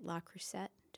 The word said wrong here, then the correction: Le Creuset: Did I say it right? – Le Creuset. – Le Creuset. – Yeah. – I knew I didn't say it right.